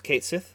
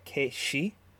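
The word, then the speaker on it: Cait Sith: Do you know that Kate Sith is actually pronounced Kate-she? – I knew it wasn't Kate Sith. I didn't know what the actual pronunciation was. – I think I'm still getting it wrong, but the Sith is she, like Gaelic.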